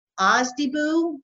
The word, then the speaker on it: OSDBU: It's OSDBU. Is that how that's pronounced? – That's how it's pronounced.